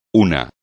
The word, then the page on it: una: espagnol